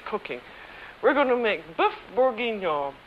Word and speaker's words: bœuf bourguignon: And, of course, the episode was about beef bourguignon. And I may be butchering the French pronunciation here. How do you say it, Alex? – It's bœuf bourguignon.